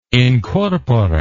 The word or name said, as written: incorpore